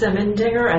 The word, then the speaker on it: Simendinger: Cannicelli is spelled C-U-N-I-C-E-L-L-I, and I'm with FCIC. – I'm Alexis Simmendinger, and that's spelled S-I-M-E-N-D-I-N-G-E-R, and I'm part of the FCIC writing team.